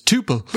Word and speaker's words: tuple: The Stanford, the guy in the Stanford course, he says underbar instead of underscore, but he also says tuple. – Which is right!